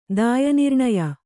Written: ದಾಯನಿರ್ಣಯ

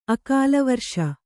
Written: ಅಕಾಲವರಿಷ

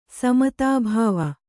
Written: ಸಮುದ್ಭವ

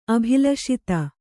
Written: ಅಭಿಲಷಿತ

